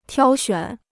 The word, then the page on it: 挑选: tiāo xuǎn